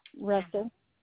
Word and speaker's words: Russell: Catherine, K-A-T-H-R-Y-N. – Mm-hmm. – Russell. – Spell that. – R-U-S-S-E-L-L.